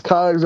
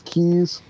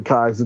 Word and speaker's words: Keyes: Oh, Remington, K-E-Y-E-S. It's pronounced Kies. Everyone says Keys, but it's really Kies. It's German.